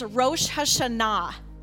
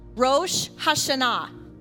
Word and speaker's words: Rosh Hashanah: I'm going to try to teach us how to say it the correct way. It's Rosh Hashanah. Rosh Hashanah.